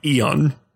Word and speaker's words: Aeon: Aeon Flux, Aeon Flux, um in, in the movie they pronounce her name Eon. Um but it starts with an A.